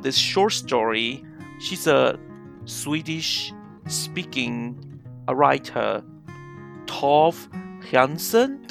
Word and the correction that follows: Tove Jansson: Do I pronounce her name correctly? Uh, well, kind of, yeah. uh, so what's her name? Sorry, would you would you like to say yeah, it? In Swedish, her name. pronounce it uh, Tove Jansson, and mm-hmm. in English, I guess Tove Jansson.